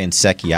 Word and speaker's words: Nsekhe: All right, let's jump to offensive tackle Ty Nsekhe. I hope I'm pronouncing that correctly. Yep. Is that yes. Mm-hmm. Correct. All right, so he was an undrafted uh, player back in 2012 with the Indianapolis Colts.